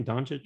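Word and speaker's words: Dončić: Doncic, is that's how you say his name? Doncic? – Yeah.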